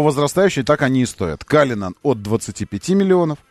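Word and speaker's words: Cullinan: И он называется Каллинан, и пишется тоже Каллинан. Но они произносят Каллинан.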